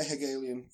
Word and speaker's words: Hegelian: Something like what the Hegelian philosophy means. Did I say that right? – Yeah, Hegelian philosophy, yeah.